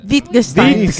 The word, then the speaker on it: Wittgenstein: Wittgenstein.